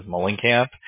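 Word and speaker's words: Muhlenkamp: And you'll see like my last name is Mullenkamp. And it's spelled like M-U-H-L-E-N. Oh.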